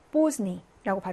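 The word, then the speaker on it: bosni (поздний): 자, 발음할 때 대가 무음입니다. 자음, 자음, 자음이 연달아 오면 하나의 자음이 생략되는 경우가 많잖아요. 그래서 bosni라고 발음하시면 되고요.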